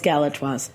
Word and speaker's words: Galatoire's: Am I saying it right? – It is Galatoire's. – Okay. – And it is a magical place. – I don't know. You looked at me funny, and pronunciation is not always my strong point, so I'm glad I got it right.